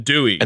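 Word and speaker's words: Due: Arduino do. – Oh, yeah. Talk to me about but the it's Dewey. Not pronounced do. No, it's Dewey.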